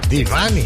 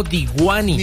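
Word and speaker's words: Deewane: Así se dice. Divani. – Divani. – Repeat after me, please. – No, Diwani. – Diwani, no. – Divani. – Divani. – Divani. Divani. Esa es la pronunciación.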